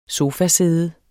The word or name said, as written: sofasæde